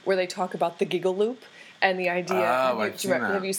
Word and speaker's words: giggle loop: That is spelled the giggle loop. – Loop. – You kind of say it like one word.